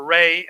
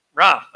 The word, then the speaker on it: Ra: If you watch Night at the Museum, Amun-Re, Amun-Ra, you know, that kind of thing, that Ra is, or Re. I've seen it both ways. I'm not sure, which is the correct pronunciation. Ra. Okay, we're going to go with Ra then.